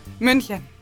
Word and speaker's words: München: München.